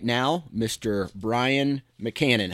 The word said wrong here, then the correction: McCammon: Wait, I think I said your name wrong, right? Is it You did, but that's all right. I'll let it slide. – Tell me. Okay, so it's Brian what? So we got to say it right. – It's McCammon. – McCammon. – With M's in the middle.